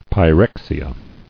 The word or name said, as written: Pyrexial